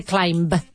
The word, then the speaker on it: climb: Climb without the B. Let's say climb.